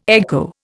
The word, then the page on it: echo: Indian